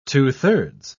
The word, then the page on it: two-thirds: British English